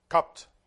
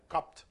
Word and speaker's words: cupped: Cupped. Yeah, it says. I don't pronounce the E here, right? Cupped. Like this. Cupped.